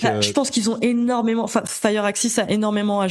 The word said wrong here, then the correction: Firaxis: jouer sur, enfin Firaxis, je le prononce toujours très mal,